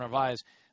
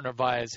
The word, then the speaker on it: Narváez: You could have a kind of a budding guy in Omar Narváez, whose name, by the way, is pronounced differently by everybody who gets to pronounce it. Some people put the R after the V where it actually is, kind of like a Brett Favre situation.